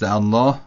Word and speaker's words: الله: در تلفظ لفظ الله خیلی مناسب هست که اینطور تلفظ کنیم، الله اکبر، یعنی یه خورده غلیظ.